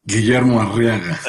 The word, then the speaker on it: Guillermo Arriaga: Guillermo Arriaga. – There you go. And after excellent pronunciation.